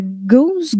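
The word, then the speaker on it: gueuze: Parce que d'origine, la, la gauze, gauze, ça s'écrit G-U-E-U-Z-E e et non pas G-O-S-E.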